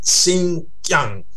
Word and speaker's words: Xinjiang: Había habido mucha represión contra los sectores independentistas en Xinjiang, o acá dice, se pronuncia Xinjiang.